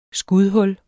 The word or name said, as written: skudhul